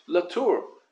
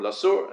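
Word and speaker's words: latur (לתור): Rather, the term that is used is latur. Latur or lasur, an Ashkenazi pronunciation, and v'yosuru es eretz kanan, latur means to take a look at something as a preview, as a prelude.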